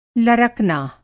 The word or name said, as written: लरकना